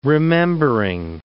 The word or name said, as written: remembering